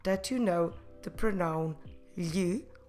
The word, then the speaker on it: lhe: L H E lhe. I know this one, it's not a very easy pronunciation. We don't have any similar sound in English that we can use to compare. So I'll try to repeat and I will repeat and you'll try to follow.